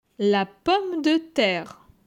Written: La pomme de terre

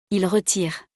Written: il retire

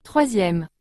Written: 3e

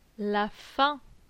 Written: La faim

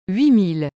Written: 8000

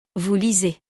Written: vous lisez

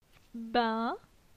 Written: Bin

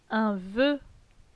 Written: Un voeu

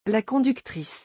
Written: la conductrice

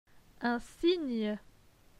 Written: Un cygne

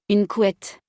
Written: Une couette

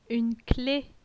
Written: Une clef

